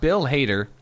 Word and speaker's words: Bill Hader: Well, Bill Hader, I think that's how you pronounce his name. – Yep. – Bill Hader is going to be Alpha 5.